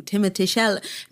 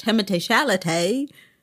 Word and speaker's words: Timothée Chalamet: I'm saying the name you're saying, Timothée Chalamet. Maybe you don't understand because it's the classical French pronunciation, but Timothée Chalamet.